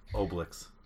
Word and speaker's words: Obelix: It's gonna be oblique. Is that how you say it? Oblique? – Obelix. – Obelix. – Obelix. – Obelix. It's French, goddamn it. I don't know French.